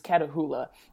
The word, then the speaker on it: Catahoula: So Zulu, yeah, so the way that you pronounce his breed is Catahoula.